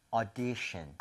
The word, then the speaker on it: audition: 그래서 이거 발음하실 때도요. 오디션이 아니라 5에서 조금만 더 벌려주시면 돼요. 그래서 audition, audition 조금만 벌려서 5보다는 차라리 어에 더 가까운 게 낫습니다.